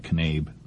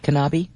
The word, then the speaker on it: Knabe: Well, it's not Knabe. – Is it Kanabi? – Yeah, it's Kanabi.